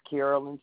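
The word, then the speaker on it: Karolyn: The name is Carolyn, spelled with the K, K-A-R-O-L-Y-N. Last name, Bryant, B-R-Y-A-N-T.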